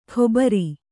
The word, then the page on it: ಕೊಬರಿ: Kannada